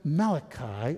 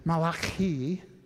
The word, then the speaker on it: Malachi: But there were some questions as to who the author was, because the very term Malachi, or if we pronounce it correctly, Malachi, see, it's a little closer to the Italians than you thought.